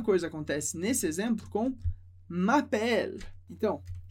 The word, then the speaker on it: m'appelle: M'appelle significa me chamo, eu me chamo. Perceba que a pronúncia do primeiro é mais fechada, s'appeler, e a segunda bem aberto, m'appelle, m'appelle.